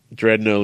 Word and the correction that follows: Drendo: lives on. – Drendo and Bredno. You don't even say it right. – I keep. – Messing it up. Drendo and Bredno. That's right.